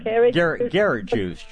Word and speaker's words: Garrett Juice: I've given it carrot, I'm not saying it right. – Garrett juice. G like Gary. Garrett juice.